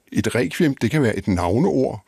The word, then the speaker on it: requiem: Og at stave requiem med KV svarer til den danske udtale, som vi bruger sådan i daglig tale i Danmark, altså som vi gør lige nu, mens vi taler om emnet, vi siger requiem.